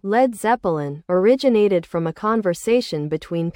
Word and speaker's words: Led Zeppelin: Page, seizing on the phrase, replaced balloon with Zeppelin and dropped the A from lead to avoid pronunciation confusion. Thus, Led Zeppelin was born, a name that perfectly captured the band's heavy, soaring sound.